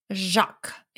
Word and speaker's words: Jacques: Jacques.